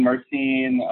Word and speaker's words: myrcene: Mycrine. Okay. I was saying it wrong. – Mycrine. – Okay. Mycrine, myrcene. I mean, the scientific term is myrcene.